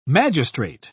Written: magistrate

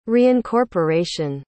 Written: reincorporation

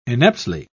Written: ineptly

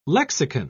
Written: lexicon

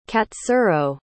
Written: katsuro

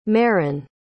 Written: maren

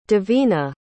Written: davinah

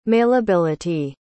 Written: mailability

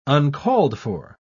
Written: uncalled-for

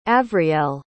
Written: avriel

